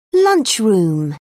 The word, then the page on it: lunchroom: British English